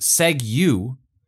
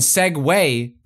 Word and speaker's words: segue: Segue, which was pronounced seg-you, and segue, pronounced segue.